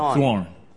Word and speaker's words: thorn: And my daughter says, well, I am like the throne in Paul's body, so I am to you. The thorn. – Mm-hmm. – Sorry, can't pronounce that word.